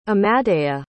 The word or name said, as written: amadeya